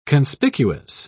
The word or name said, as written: conspicuous